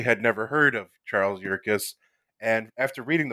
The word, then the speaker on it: Yerkes: Yeah, so Charles Yerkes.